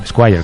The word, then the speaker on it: Esquire: creo que era el Esquire, creo, ¿no? Donde hablaba de su adicción al alcohol. – Qué bien lo has pronunciado.